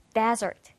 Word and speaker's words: desert: desert, desert. 자, 강세가 앞에 있고요. 뜻은 모래가 덮여 있는 곳, 바로 사막이라는 그런 뜻이 있습니다.